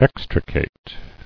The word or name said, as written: Extrication